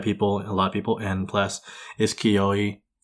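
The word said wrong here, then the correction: Kyoani: Like, people are going to support Kiyoani. – It. Kiyoani. Fuck, I keep pronouncing it wrong.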